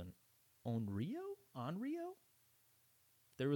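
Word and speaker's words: Onryo (怨霊): I think Onryo is like. – Oh, okay. – That's how it's pronounced.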